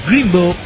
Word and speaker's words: Greenville: Pero esa aclara, Greenville, pronunciación. – Ah, Greenville.